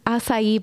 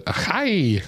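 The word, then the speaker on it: açaí: I've never said it correctly. Yeah. Even with you saying it a couple times here, I still want to pronounce it achai.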